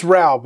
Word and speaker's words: Raugh: It's Raugh, but it doesn't matter. Raugh.